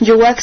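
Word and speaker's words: Youak: Yowak se escribe Y-O-U-A-K. Y-O-U-A-K, así nos encuentran en Facebook, Ensamble Yowak.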